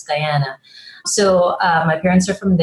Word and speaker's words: Guyana: I was born in Guyana.